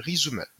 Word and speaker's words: Resoomer: Euh, il s'appelle euh, Resume. Donc ça s'écrit R-E-S-O-O-M-E-R.